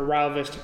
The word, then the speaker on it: Rio Vista: God, I keep mixing it up because I know the locals say Rao Vista, but everyone else says Rio. I'm just gonna stick with the locals here, Rao Vista.